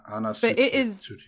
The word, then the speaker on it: Tsuchiya: Yeah, so, like, the OP, for those who don't know, is Switch On by Anna Tsuchiya, I think is how you pronounce her last name. – Anna Tsuchiya, yeah.